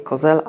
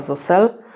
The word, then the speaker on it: Zosel: Okazuje się, że się wymawia nazwisko nie Kozel, a Zosel.